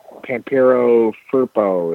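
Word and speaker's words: Pampero Firpo: And he had Pampiro Furpo. Am I saying his name right? Yes.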